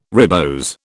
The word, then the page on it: ribose: British English